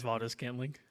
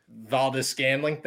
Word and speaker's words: Valdes-Scantling: Marquez Valdes-Scantling. – Valdes-Scantling, thank you.